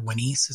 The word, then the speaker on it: Wenis: or the causeway of Unas or Wenis, as it would have been pronounced.